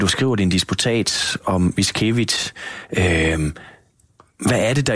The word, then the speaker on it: Witkiewicz: Øh, du fortsætter da i hvert fald, kan man sige, øh, øh, interessen, og det er jo så Stanislav hvordan udtaler vi ham? Vitkevich? Nej, Witkiewicz. Witkiewicz.